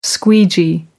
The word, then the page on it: squeegee: American English